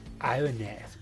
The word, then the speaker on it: Ibanez: Ha llegado la hora de hablar del flaco Ibáñez, ¿no? ¿Vamos a hablar? – ¿O de qué me habías dicho que íbamos a hablar? Pero, güey, no se dice Ibáñez, se dice Ibanez, güey.